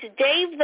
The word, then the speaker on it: Damh: And it's Dave the Bard, but it's spelled D-A-M as in money, D-A-M-H. That is a Gaelic pronunciation. So D-A-M-H looks like damn, but it's not the bard.